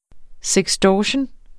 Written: sextortion

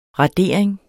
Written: radering

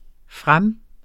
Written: frem

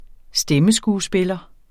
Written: stemmeskuespiller

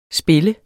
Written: spiller

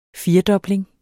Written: firedobling

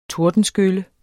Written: tordenskylle

